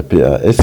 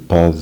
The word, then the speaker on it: pas: Wenn danach ein Vokal kommt, dann wird das S ausgesprochen, also je ne suis pas une. Und wenn man sagt, ich bin nicht krank, je ne suis pas Malat, da wird das S nicht ausgesprochen.